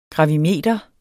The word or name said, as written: gravimeter